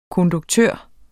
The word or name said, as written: konduktør